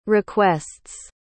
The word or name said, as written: requests